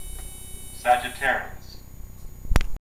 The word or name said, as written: Sagittarius